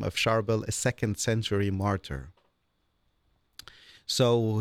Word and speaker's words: Charbel: Charbel. – Charbel.